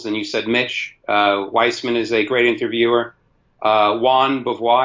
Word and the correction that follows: Jean Beauvoir: I think that's how you pronounce his name. Jean Beauvoir. Yeah, yeah.